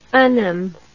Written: anum

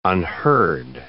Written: unheard

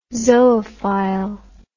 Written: zoophile

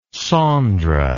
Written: Saundra

